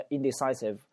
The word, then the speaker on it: indecisive: Peter 呢，非常的犹豫不决，对吧？他非常的优柔寡断，叫 indecisive。这个 save 这个发音部分一定要记住啊，咬住下嘴唇。